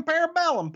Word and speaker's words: Parabellum: Well, I think the show title is correctly pronounced. Si vis pacem parabellum. – Parabellum. – That's the way the Latins would pronounce it.